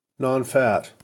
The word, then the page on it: nonfat: American English